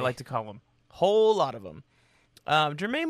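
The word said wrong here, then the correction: Germán: Marquez, I mean. – Herman? Come on, Herman, whatever. Are you for real right now?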